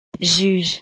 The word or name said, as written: juge